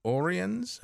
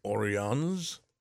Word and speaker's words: Oriens: He's written a book called Oriens. I guess, is that how you pronounce that? – I think so. – Latin, Oriens. It's a pilgrimage through Advent and Christmas, kind of a personal, you can take this yourself, which is probably good this year.